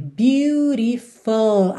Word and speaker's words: beautiful: Eu pintei a palavra toda aqui de verde, porque é sempre interessante a gente lembrar que a pronúncia é bem diferente da escrita, né? Na escrita a gente tem algo que seria como beautiful aí no português e nenhuma dessas sílabas fica igual em inglês, né? Então a gente vai ter beautiful até o final. Cuidado para você não fazer um U muito desenhado, beautiful, é beautiful, ok? Beautiful.